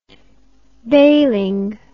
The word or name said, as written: bailing